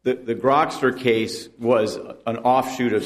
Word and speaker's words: Grokster: In Grokster, is that how you say it? – Yes.